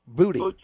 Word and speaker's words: Boutte: Booty is what how he pronounces it now.